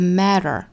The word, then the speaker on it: matter: The only thing that matter，注意 matter 这个词里面含有一个 flap t 的发音，所以发的时候一定是 matter， matter， matter， matter。